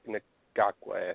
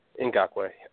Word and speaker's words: Ngakoue: They've added Dante Fowler, Miles Jack, may or may not play, Jalen Ramsey, Malik Jackson, Tayshaun Gibson, Prince Amakamura, and Yannick Ngakoue, I think is how you pronounce it, Ngakoue.